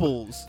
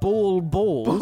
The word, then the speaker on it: baubles: Be it ball balls. – Ball balls. – No, it's baubles. No, B-A-U-B-L-E-S. Baubles.